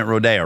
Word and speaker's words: Rodeo: And we're pronouncing it Rodeo, right? It's not Rodeo.